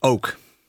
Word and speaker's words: OOAK: I'm going to ask you how to pronounce this because it's OOAK, which is a bit of a pun of a name. – Yeah, it's oak. Oak. – Oak. – As in a tree.